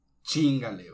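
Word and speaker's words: chíngale: Chingale, güey. Ok. Chingale con acento en la I.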